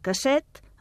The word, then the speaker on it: casset: En català s'escriu adaptat, amb doble S i acabat en T, i es pronuncia amb S sorda. La cinta és una casset, en femení, i el magnetòfon un casset, en masculí.